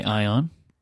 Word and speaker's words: Ion: I would say Ion, not Aon. No, because it's got the I there. Mm-hmm. – Like mm-hmm. that that would make me say Ion.